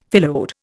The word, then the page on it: phyllode: Indian